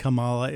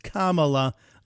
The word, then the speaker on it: Kamala: The comprehensively incapable Kamala, not Kamala, it's Kamala. Let's pronounce it right, news lady.